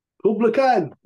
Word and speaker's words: Kublai Khan: Kublai Khan.